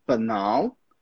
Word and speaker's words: banal: This one, banal. Banal, but that's the pronunciation.